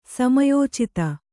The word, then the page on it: ಸಮಯೋಚಿತ: samayōcita